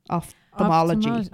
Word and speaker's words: ophthalmology: Ophthalmology. – There we go. – Is it off or opt? – Well, it's spelled off, P-H, but ophthalmology definitely sounds more like optician, which...